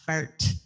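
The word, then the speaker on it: Fahrt: And I believe I did take German in high school and college, and I think it would have been pronounced, if you're supposed to pronounce it correctly, it's like fart. So fart.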